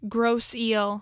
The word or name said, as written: Grosse Ile